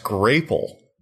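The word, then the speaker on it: Grapple: One, it's pronounced grapele. – Oh, okay. – Which I've never heard that. – I don't think anyone saw coming. – No. – They even, like, on their website, they have the little, what is it? The phonetic thing. – Yeah. – So that you know it's a hard A. Or a long A? – Long A, yeah.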